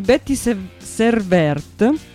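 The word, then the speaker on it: Betty Serveert: Vi parlo di Betty Servert, scritto proprio come ve lo pronuncio.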